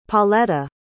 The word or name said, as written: Pauletta